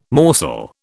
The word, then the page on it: morsel: British English